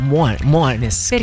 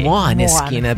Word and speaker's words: Måneskin: Anzi, ti ricordi, c'era Gabriele Corsi in Rai che a un certo punto diceva soltanto Måneskin. – Perché secondo la pronuncia in teoria dovrebbe essere quella. – Un po' alla corsivo, sì, i Måneskin, perché è una parola danese.